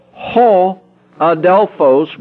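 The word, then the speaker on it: ho Adelphos (ὁ ἀδελφός): Now, if you want to say the brother, you notice that little O, that Omicron there with a little tail above it, okay? It then is pronounced ho Adelphos, which then is the brother.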